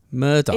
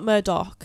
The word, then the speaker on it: Murdaugh: Murdaugh. It's not Murdoch, it's Murdaugh.